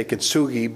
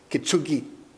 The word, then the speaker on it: kintsugi: Actually, it's, in English we say kintsugi, but the actual pronunciation is kintsugi.